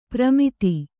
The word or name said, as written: ಪ್ರಮಿತಿ